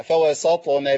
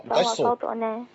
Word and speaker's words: فوسطنا: لا لا بص حضرتك فوسط. – فوسط. – أيوة وصلها بقى فوسطنا به. – فوسطنا به. – لا ما توقفش على الطاء. – فوسطنا. – أيوة بالظبط كده. – فوسطنا به جمعا.